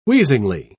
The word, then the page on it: wheezingly: British English